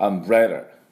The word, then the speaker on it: Umbrella: で、アンブレ、ブレンもダブルシーンのブルブルブルブルブルブルブルね、このブレのところにアクセントがあります。アンブレラということで、で最後は L ですから、ラララララ、軽めのラリルレロっていうような音を出していただきたいと思います。では私の後について発音いきましょう。 Umbrella Umbrella。